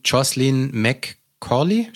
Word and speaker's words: Jocelyn McCauley: Und Jocelyn McCauley, spricht man das so aus? – Ja, das hast du wunderschön gesagt.